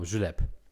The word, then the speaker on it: Julep: also das P wird ausgesprochen, was ah, mich okay. wundert. – Aber deswegen, äh, genau, Julep.